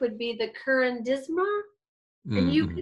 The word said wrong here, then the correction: curanderismo: could probably pronounce that a lot smoother than I just did. – It's a yeah, curanderismo in, in Spanish.